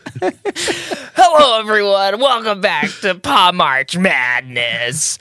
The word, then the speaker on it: Par'March Madness: Welcome back to Parmak Madness. – Did I say it wrong? I said it wrong. Welcome back to Parmak Madness. – Parmarch Madness.